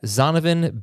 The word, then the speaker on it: Zonovan: Zdonovan White. I'm sorry. I knew I butchered his name. Zdonovan Knight. I meant to put it in the notes, and... yeah, sorry about that. Zonovan Bam Knight is his name.